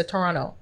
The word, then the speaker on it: Toronto: Yeah, they don't pronounce the T, so they say Toronto. Well, the second T.